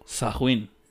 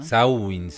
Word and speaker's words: Samhain: Pero la pronunciación es como Sahuin. ¿no? Sawin, algo así.